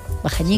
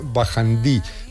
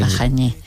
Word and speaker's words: Bajañí: También a veces se escribe y se pronuncia Bajandí, pero bueno, creo que aunque se escribía Bajandí, también se seguía pronunciando en Bajañí.